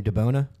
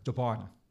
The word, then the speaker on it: DiBona: So, all right, we have Mark, and you say your last name, DiBona? – DiBona. – DiBona. – DiBona, yep. – Okay, cool. Yep. I was butchering that.